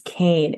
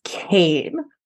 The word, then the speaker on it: Caine: His name is Kane. And the fact that they spell it C-A-I-N-E does not change the fact that his name is Kane.